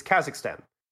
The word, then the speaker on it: Kazakhstan: You don't know how to pronounce the country name. It's Kazakhstan.